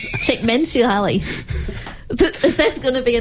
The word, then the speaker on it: Csikszentmihalyi: Also, this article talks about, there's a psychologist, I can't say his name, Mihaly Csikszentmihalyi. – I can do this. Csikszentmihalyi.